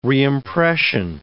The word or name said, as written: reimpression